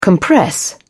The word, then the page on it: compress: British English